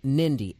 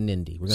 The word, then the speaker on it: Hnidy: I believe his name is pronounced Shane Nindy, H-N-I-D-Y. So we're going to go with Nindy. – Sounds good. – We're going to say the H is silent on this.